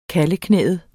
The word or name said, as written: kalveknæet